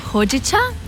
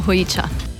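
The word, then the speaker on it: hojicha: V bistvu Japonci izgovorijo hojiča, pri nas pa je v bistvu se lepše čuje, da tudi ljudje razumejo in uporabljamo hojiča.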